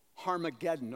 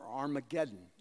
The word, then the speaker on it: Harmageddon: And they gathered them together to the place which in the Hebrew is called Harmageddon or Armageddon. It depends how you uh, pronounce it, but it has an H sound in the Greek.